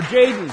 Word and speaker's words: Jadon: Come share, Jason. Or Jaden, excuse me. And just so you know, it is spelled J-A-D-O-N, correct? – Yes.